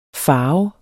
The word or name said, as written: farve